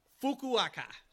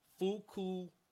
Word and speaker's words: Fukuoka: Fukuoka, double crazy. That's spelled F-U-K-U-O-K-A. Fukuoka.